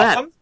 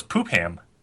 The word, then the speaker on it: Popham: And I am there at That's yeah, wait, try to Google Popham? That. It's pronounced Popham. – I thought it was poop ham. – No, it's not.